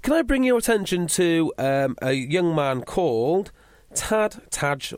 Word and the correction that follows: Ty: or however you say his name? – Ty McElroy.